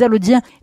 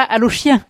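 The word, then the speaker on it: Allaudiens: Et il faut aussi préciser que on dit Allodiens et non pas Allochiens. – Oui, tout à fait. Allo s'écrit avec un CH à la fin. Alors, ça se prononce pas. Hein. Les gens de la région le savent. Quand les gens viennent de Paris, c'est des fois plus compliqué. Ils ont le réflexe de dire Alloche.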